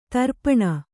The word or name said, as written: ದರ್ಪಣ